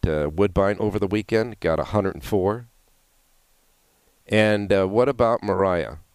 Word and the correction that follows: Mora: Or Mora, I should say. Mora. Keep pronouncing her name wrong. Better get it right soon.